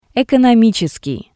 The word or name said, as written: Economitseskii